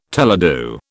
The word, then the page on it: teledu: British English